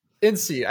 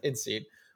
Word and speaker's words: INSEAD: And so what I looked at is a study that was done at, do I pronounce it INSEAD or INSEAD? Anyway, the Research Institute. – I'll tell you. – INSEAD. I think it's pronounced INSEAD, which looked at what happens when people need to develop ideas at a company.